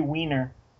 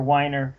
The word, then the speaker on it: Weiner: It's like Matthew Weiner or Weiner for Mad Men. Like, they keep changing the pronunciation of his name just because Weiner sounds funny.